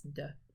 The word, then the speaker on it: folie à deux: I looked up both the American pronunciation and the British pronunciation, and it's duh.